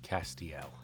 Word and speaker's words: Castiel: He is, of course, an actor best known for his role as the Angel Castiel. Did I pronounce that right? – Castiel.